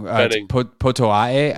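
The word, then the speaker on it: Potoa'e: Potoae, I believe, is the correct pronunciation.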